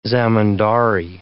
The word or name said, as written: zamindari